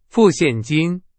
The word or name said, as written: 现金